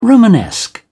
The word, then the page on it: romanesque: British English